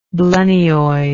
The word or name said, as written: blennioid